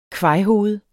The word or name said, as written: kvajhoved